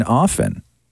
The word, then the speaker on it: often: It's just like when people pronounce the T in often. – Often.